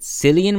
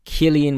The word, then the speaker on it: Cillian: By the way, Americans, yes, that is how you pronounce his name. I do not know why people keep pronouncing it Cillian Murphy. He's an extremely famous actor. Don't know how you missed it. But anyway, regardless of it, it's pronounced Cillian Murphy. Cillian Murphy is how you pronounce it.